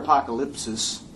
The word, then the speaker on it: apokalypsis: That's what the word apokalypsis, is how it would be pronounced in the Greek text.